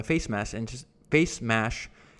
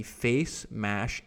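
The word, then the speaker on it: FaceMash: Mark had obviously learned his lesson from the face mash incident. I don't know why I can't say that word. I keep mispronouncing face mash. There we go.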